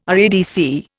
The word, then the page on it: RADC: Indian